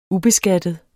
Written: ubeskattet

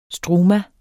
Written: struma

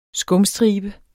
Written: skumstribe

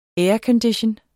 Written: aircondition

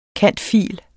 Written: cand.phil.

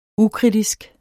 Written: ukritisk

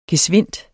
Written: gesvindt

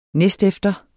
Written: næstefter

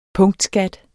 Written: punktskat

